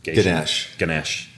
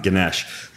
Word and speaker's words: Ganesh: I know it comes back to the protocol that I butchered, Garshin. – Ganesh. – Oh, I was close. And it's not a doctor. It's a Hindu god, Ganesh.